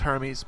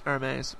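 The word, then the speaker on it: Hermès: But when he opens it, he finds two teacups and saucers from the label Hermes, and I had to look that up, how to pronounce that word, because I thought it was Hermes, but it's Hermes, apparently.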